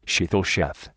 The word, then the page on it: sheetal sheth: American English